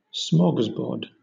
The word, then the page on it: smorgasbord: British English